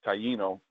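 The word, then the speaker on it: Taíno: The word Taino, which is actually like a Spanish pronunciation, which is odd, um, it actually meant the good ones are good people.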